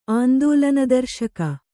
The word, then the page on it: ಆಂದೋಲನದರ್ಶಕ: āndōlanadarśaka